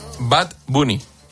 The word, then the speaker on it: Bad Bunny: Bad Bunny.